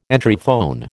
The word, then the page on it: Entryphone: American English